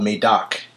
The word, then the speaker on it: Médoc: And the left bank is, you have the main region, it's called Medoc, and it's spelled M-E-D-O-C. kind of sounds just like it's spelled, actually, Medoc.